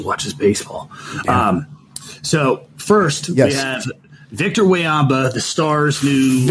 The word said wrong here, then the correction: Wembanyama: Very close. Pronounce his name again for me. – Wayamba. – Wayamba? I believe it's Wembanyama.